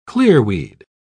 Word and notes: clearweed: British English

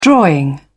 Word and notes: drawing: British English

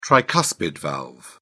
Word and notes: tricuspid valve: British English

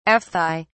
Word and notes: aphthae: British English

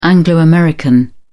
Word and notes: anglo american: British English

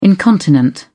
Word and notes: incontinent: British English

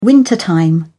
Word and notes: wintertime: British English

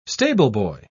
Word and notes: stableboy: British English

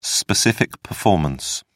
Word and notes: specific performance: British English

